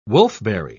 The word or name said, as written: wolfberry